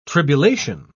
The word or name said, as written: tribulation